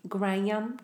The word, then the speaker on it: Graham: Graham? – Graham. Can I talk to Graham? – Yeah, it's Graham. Two syllables.